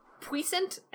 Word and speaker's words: puissant: It's puissant, and it's an archaic word for powerful. – Yeah, well, in French it would be puissant.